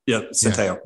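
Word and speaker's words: Centeio: I hope I'm saying that right, Santeo. – Yeah, Santeo.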